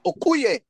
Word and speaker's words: Okoye: Okoye, bro, from from Black Panther. Oh, you're talking about Okoye. Yeah, that, so that's the only way you can pronounce it.